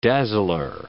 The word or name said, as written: dazzler